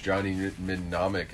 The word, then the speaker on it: Mnemonic: I'd probably watch it before I ever watch Johnny Mnemonic again. Mnemonic? – I think it's Johnny Mnemonic. I think the first M is silent.